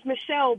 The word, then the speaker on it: Mechelle: Well, I don't know, because my name is Michelle, but it's spelled M-E-C-H-E-L-L-E. And typically it's spelled M-I.